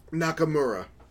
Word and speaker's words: Nakamura: Masaya Nakamura.